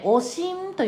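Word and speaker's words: 悪心: 腹痛や下痢、発熱、おしんと読みます。悪い心と書いておしんです。おえってやつです。吐き戻すまではいかないけれども、うっと気持ち悪くなるやつね。でそれを超えてくると嘔吐ってなってきますね。なんかちょっと胸がムカムカするとか気持ち悪いみたいなのがおしんです。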